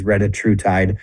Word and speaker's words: retatrutide: So we've heard it pronounced 100 different ways. The one that I like to go with is retatrutide.